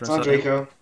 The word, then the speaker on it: Andreyko: Did I, did I nail Andreyko? Is that, the, is that how you spell it? Yep, Andreyko.